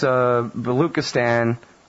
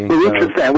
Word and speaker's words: Baluchistan: And this Baluchistan, I keep saying it wrong, pardon me. – Baluchistan, which is part of Pakistan.